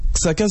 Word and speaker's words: Xhaka: Xhaka, c'est comme ça qu'on prononce, ouais.